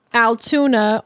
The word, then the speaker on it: Altoona: Never thought this is what would get good old Altoona on the map. Did I say that right? – Yeah. Yes.